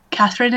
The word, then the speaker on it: Kathryn: Kathryn is spelt with a K and a Y.